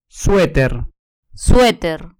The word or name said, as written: sueter